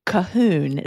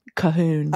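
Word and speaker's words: Colquhoun: My family, I'm a Cahoon. Is how you pronounce it. – Nice. – C-O-L-Q-U.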